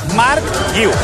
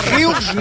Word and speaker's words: Guiu: ¿Cómo se dice que me lo tengo que aprender? Me lo tengo que aprender. – ¡Apunta! – Marc Guiu. Guiu. Fácil.